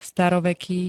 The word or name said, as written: staroveký